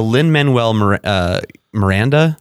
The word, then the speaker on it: Lin-Manuel Miranda: Is the Lin-Manuel Miranda.